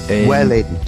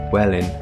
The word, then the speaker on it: Welwyn: Welwyn. – Welwyn. – Yeah. – All right, okay. Is this spelled W-E-L-W-Y-N? – Yes. Welwyn, it's Welwyn Garden City.